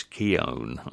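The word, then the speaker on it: Keoghan: Andrea Arnold's latest film, which stars Barry, and I was reading an article about this the other day, how you pronounce his name, and he says Keown.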